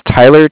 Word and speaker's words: Tyler: Yeah, my name is Tyler, T-Y-L-E-R. – T-Y-L-E-R.